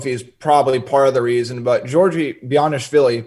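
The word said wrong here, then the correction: Bezhanishvili: did I pronounce that right? I'm going to be really happy if I did. Bianishvili? – Bishanishvili, yeah.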